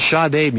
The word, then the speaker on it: Sade: And Sade music. – Sade. – It is Sade. Sade is not correct. – Is it? I've always called it Sade.